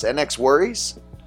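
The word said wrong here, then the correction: NxWorries: Oh, yeah. No Worries.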